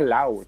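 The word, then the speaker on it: Lowe: se, pronuncia Lau, todo el mundo dice Lau y es Lau porque hay que oír la radio de Tampa para saberlo.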